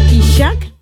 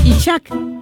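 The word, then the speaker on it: Ishak: Muchachos, se escribe I-S-H-A-K, o sea, Itzhak. Itzhak.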